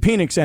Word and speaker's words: Penix: Penix. – And like Pat McAfee said at the end of the clip, I thought I was pronouncing his name wrong or something. And they're like, nope, no you weren't.